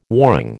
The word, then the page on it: warring: American English